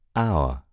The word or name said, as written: hour